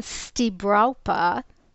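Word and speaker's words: Stibrawpa: You can Google Stibrapa, which is spelled S-T-I-B as in Bill, R-A-W-P as in Peter, A. That's Stibrapa.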